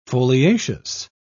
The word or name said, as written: foliaceous